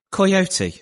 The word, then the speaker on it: Coyote: Can you just pronounce Coyote again for me, please? – Coyote. It's a soft Y.